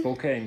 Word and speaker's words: Spokane: Gonzaga Universität in Spokane. – Spokane, genau richtig, ja, da war ich.